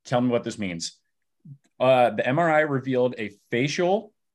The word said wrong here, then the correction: fascial: You're talking about fascial. Is I think the way I would pronounce that. – Fascial, yeah, yes.